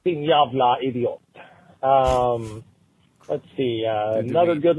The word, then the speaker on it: jävla: and when you pronounce it in Swedish, it is jävla, J-A with umlaut, V-L-A.